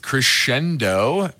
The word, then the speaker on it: Crexendo: Crescendo. Pretty interesting spelling. CXDO is the symbol, and it's spelled CREXENDO.